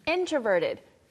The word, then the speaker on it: introverted: 이 두 가지 단어 발음할 때 발음 팁 하나 드리면 앞에 이제 intro가 나오고 extro가 나오지만 row처럼 발음하지 않고 그냥 no. intro. – Intro. Yeah. 네. Introverted. 네. Extroverted. – Like 사전에서 bro. extra. – 그렇죠. – Intro. – 네. 어 발음이 나와요. 사전에서 발음기호로 보시면은 intro가 oh. 아니고요.